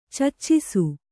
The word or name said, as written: ಚುಚ್ಚಿಸು